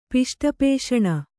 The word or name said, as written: ಪಿಷ್ಟಪೇಷಣ